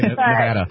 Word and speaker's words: Nevada: If you can't pronounce Nevada, I don't even trust that you're going to know about it. – You got to say Nevada.